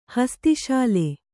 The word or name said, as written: ಹಸ್ತಿಶಾಲೆ